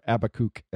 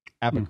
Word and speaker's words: Habakkuk: No, that's the British pronunciation. Mm. Abakuk. – Mm. Okay. – And actually the Hebrew pronunciation as well, frankly.